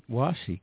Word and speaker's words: washi: Did I pronounce it right? Washi? – You did. Washi.